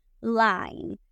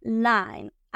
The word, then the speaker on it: line: Aquí la I, la letra I, suena con el nombre. El nombre de la letra I es I. Entonces vamos a pronunciar la I como I. En lugar de li, decir lean, vamos a decir line, line.